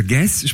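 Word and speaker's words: Guez: Der schreibt sich G-U-E. G-U-E-Z, genau. Also Guess spricht man das aus, das ist ein Franzose, kann aber sehr gut Deutsch, hat lange in Deutschland studiert.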